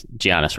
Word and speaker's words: Giannis: And Giannis, right? Did I say that right? – I believe the G is silent.